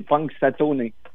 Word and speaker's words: Punxsutawney: D'abord, je veux que tu me dises, ben, prononce-moi le donc comme il faut le nom de la ville. – C'est Punxsutawney.